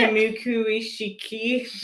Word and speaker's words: Kamikuishiki: K-A-M-I-K-U-I-S-H-I-K-I. – You're not going to pronounce it? – Kamukuishiki.